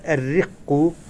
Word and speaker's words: الرق: عنوان الصحيح الرق في الإسلام. – ألف لام. – ألف لام الرق، لا تلفظ اللام.